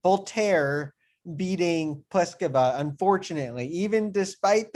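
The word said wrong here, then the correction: Boulter: You know, she's British. It's exactly like it looks. It is just Bolter.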